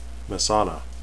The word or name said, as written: mesaana